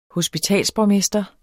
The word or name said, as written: hospitalsborgmester